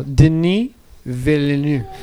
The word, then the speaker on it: Denis Villeneuve: Denis Villeneuve. – That was perfect pronunciation.